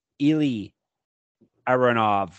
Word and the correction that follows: Eli Aronov: Yeah, and it's Eli Aronov, is how you say his name. – Eli, excuse me.